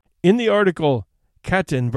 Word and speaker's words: Katyn: Katyn, which is pronounced as written, is a town about 15 miles west of Smolensk.